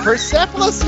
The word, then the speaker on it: Persepolis: Number 7 was Persepolis. Did I say it right? – Persepolis, yes!